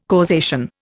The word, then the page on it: causation: Indian